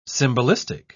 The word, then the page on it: symbolistic: British English